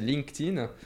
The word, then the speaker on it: LinkedIn: Parce que pourtant, c'est assez simple, hein, c'est LinkedIn.